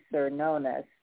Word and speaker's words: Cernunnos: And the card is CERNUNNOS. So I'm going to say Cernonus, but I don't know if I'm pronouncing it right. Oh, it says Cur. It tells me Cernunnos. And Cernunnos was also known as Herne, H-E-R-N-E, which is a Celtic stag god who represents connection to Earth.